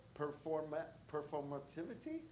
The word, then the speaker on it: performativity: then you get a sense of maybe some sort of performativity. Did I say that right? – You got it.